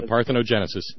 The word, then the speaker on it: parthenogenesis: Yeah, parthenogenesis.